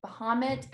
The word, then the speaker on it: Bahamut: Um, Bahim. Bahamut. I always say that wrong.